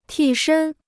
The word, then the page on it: 替身: tì shēn